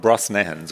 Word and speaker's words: Brosnahan: Erfunden von Amy Sherman Palladino und Rachel Brosnahan, so spricht man sie, Brosnahan, so spricht man sie aus, ist Miriam Maisel und The Marvelous Mrs. Maisel, Amazon Prime.